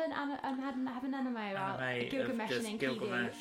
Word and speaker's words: Enkidu: Enkidu.